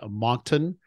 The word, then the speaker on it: Moncton: He lives, let me see if I don't butcher the name of the city, he lives in Moncton, New Brunswick, Canada. Did I get that right? – Yeah, you did, yeah.